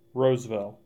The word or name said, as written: Roseville